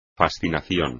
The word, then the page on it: fascinación: espagnol